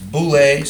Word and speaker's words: boule: The counsel of his will comes from a Greek word, it's boule, so, So boule, something like that, or even pronounce it like that, boule.